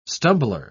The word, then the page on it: stumbler: British English